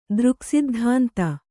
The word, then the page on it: ದೃಕ್ಸಿದ್ಧಾಂತ: dřksiddhānta